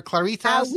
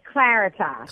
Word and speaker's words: Claritas: Well, Claritas, do I say it properly? Claritas or Claritas? – We say Claritas. – Claritas. – Yes, Claritas Classical Academy. – So not to be confused with Claritas, like Rita's water ice.